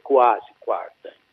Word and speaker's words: Kwasi Kwarteng: Allora, intanto la pronuncia giusta, che è quasi quarta, e poi cosa, scusa la correzione.